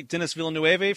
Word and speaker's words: Denis Villeneuve: We have Damien Chazelle, La La Land, Barry Jenkins for Moonlight, Kenneth Lonergan for Manchester by the Sea, Denis Villeneuve for Arrival. Did I say that right? – I think so. – It sounds Spanish, even though he's French.